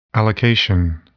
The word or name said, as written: allocation